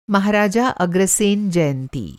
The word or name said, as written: महाराजा अग्रसेन